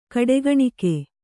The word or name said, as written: ಕಡೆಗಣಿಕೆ